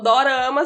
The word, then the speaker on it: dorama: Aí eles não conseguem pronunciar o D e o R junto, de drama. Então, dorama significa, tipo, um drama, só que.